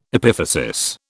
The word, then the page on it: epiphysis: British English